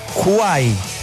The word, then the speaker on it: Kwai: Se escribe K-W-A-I latina, pero se pronuncia Kuai.